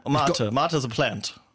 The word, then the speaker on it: Mate: And Club Mater, is that how you say it, or Mate? – Mater. – Mater.